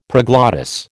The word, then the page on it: proglottis: American English